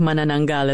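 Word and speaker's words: Manananggal: And I gotta say that your pronunciation of Mananangal is quite impressive.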